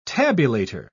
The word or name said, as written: tabulator